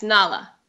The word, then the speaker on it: Nala: Is that how you say your name, Nala? – Yes, Nala.